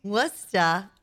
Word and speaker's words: Worcester: Worcester. What? – Worcester.